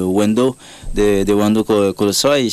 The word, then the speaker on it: Wendo Kolosoy: En parlant des aînés, des gens qui t'ont inspiré, tu m'as parlé aussi de Wendo Kolosoy, je pense que c'est comme ça se prononce. – Oui, oui.